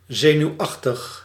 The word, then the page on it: nerveux: [nɛʁ.vø]; France